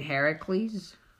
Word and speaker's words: Heracles: You mean Heracles? – Shut up. – Weird. – That is actually how it's pronounced, though.